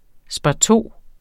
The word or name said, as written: sparto